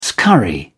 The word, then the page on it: scurry: British English